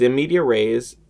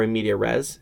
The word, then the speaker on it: in media res: Okay, so the the episode starts with, um, since you forgot, it's in media rays um, or in media res. How do you pronounce that? Is it res or rays? – I think it's res.